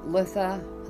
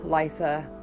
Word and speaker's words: Litha: So, first off, it's pronounced Litha, Lisa, Lisa many ways. I say Litha. If you say Litha, that's awesome. You say Letha, whatever. I just always call it Litha. That's what I was taught.